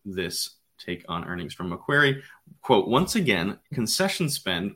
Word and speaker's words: Macquarie: Analysts at Macquarie, I believe I spelled that.